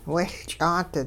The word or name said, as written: wehLch'aat